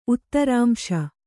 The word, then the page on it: ಉತ್ತರಾಂಶ: Kannada